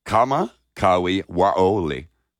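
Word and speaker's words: Kamakawi Waole (Kamakawiwoʻole): Kamakawi Waole, born in 1959. Pronounce all the vowels if you're using Polynesian names.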